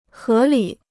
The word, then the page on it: 合理: hé lǐ